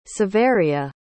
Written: savaria

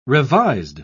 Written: revised

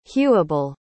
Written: hewable

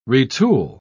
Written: retool

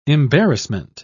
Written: embarrassment

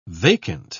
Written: vacant

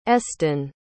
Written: eston

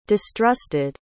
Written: distrusted

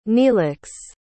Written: neelix

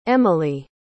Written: emilie

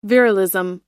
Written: virilism

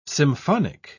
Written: symphonic

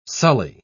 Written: sully

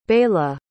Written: Beyla

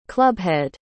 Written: clubhead

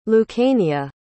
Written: lucania

